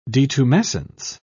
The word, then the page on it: detumescence: British English